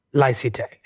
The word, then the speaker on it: laïcité: which is called laïcité. – And what is laïcité? And am I saying it correctly? Laïcité. – Laïcité, yeah, that's fine this way.